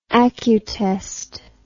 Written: acutest

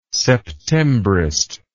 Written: septembrist

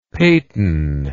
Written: peyton

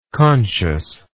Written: consciously

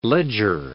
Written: leger line

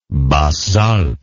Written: Basses Alpes

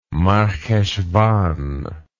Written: marheshvan

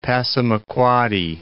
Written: Passamaquoddy Bay

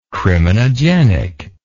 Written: criminogenic

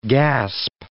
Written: gasp

